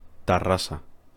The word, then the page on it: Terrasa: Spanish